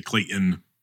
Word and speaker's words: Clayton: Even when I say even when I say my name, I don't pronounce the T. I say Clayton, like like it's an I N.